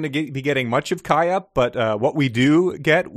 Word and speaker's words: Kaaya: I thought it was, yeah, Kaya, but it's spelled K-A-A-Y-A. – Kaya. Yeah. So that always trips me up. – Yeah. – But it's just Kaya.